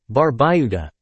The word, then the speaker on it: Barbuda: Antigua, pronounced Antigua, and Barbuda, Barbuda, is located in the heart of the Caribbean Sea.